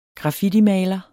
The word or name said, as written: graffitimaler